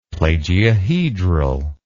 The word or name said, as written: plagiohedral